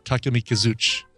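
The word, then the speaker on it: Takemikazuchi (タケミカヅチ): Because in Japan, you don't actually put any emphasis on any syllable, so Takemikazuchi.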